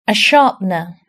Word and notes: sharpener: British English

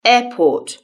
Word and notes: airport: British English

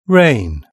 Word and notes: rain: British English

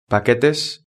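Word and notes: paquetes: European Spanish

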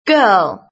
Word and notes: girl: British English

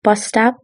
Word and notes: bus stop: British English

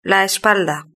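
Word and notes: la espalda: Spanish (Spain)

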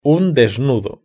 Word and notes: un desnudo: Spain Spanish

